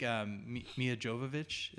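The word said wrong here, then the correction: Milla Jovovich: is how you say her name? – Mila Jovovich, yeah.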